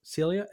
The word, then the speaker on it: SYLLA: SYLLA, is that how you pronounce it? – SYLLA, NOLLER, SYLLA.